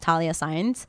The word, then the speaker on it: Thalia Saenz: and I made it very specific to just Thalia Saenz.